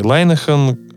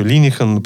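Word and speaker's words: Linehan: И DBT — классный инструмент, который придуман э, Маршей Лайнахан, ну, еще Линихан, по-разному фамилии произносят.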